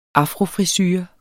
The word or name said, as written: afrofrisure